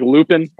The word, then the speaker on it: Lupin: It says like it's like Lupin on Netflix, but it's pronounced Lupin.